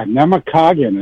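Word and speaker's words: Namekagon: We used to go up there every winter when I was a kid, and yeah, Nemecogon is how you say that.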